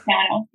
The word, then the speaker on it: Jana: It's Yana, yeah. It's the Slavic, I guess, pronunciation, because I'm Estonian. Uh-huh. And the J is, it's the same as like a Y in English.